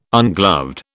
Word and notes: ungloved: British English

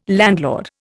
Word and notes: landlord: Indian

